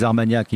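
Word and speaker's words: Arts Magnac: Rien que le nom, les Armagnacs. Ouais. Je l'ai pas dit à nos auditeurs, mais ça s'écrit Ar, A-R-T-S, Magnac, comme des maniaco-dépressifs.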